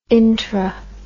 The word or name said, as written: intra